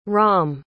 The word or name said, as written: rahm